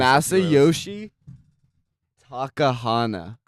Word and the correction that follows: Masayoshi Takanaka: Yeah, I butchered that. – No. – Masayoshi Takanaka.